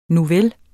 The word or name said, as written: nuvel